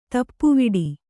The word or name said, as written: ತಾಪವಡೆ